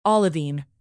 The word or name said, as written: olivine